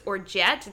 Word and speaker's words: orgeat: And an homemade almond syrup that Charles's dad made, which we have been trying to pronounce all day, which is Orgeat. It's O-R-G-E-A-T. Orgeat.